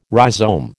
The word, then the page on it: rhizome: American English